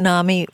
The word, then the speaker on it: NAMI: I like that NAMI rhymes with mommy.